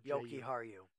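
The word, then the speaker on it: Jokiharju: And then they have, I'm not even going to try to pronounce that, J-O-K-I-H-A-R. – Yoki Haru. – Yep, that one.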